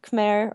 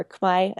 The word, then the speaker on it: Khmer: I learned a little bit of Khmer, or Khmai as they pronounce it, which is the Cambodian language.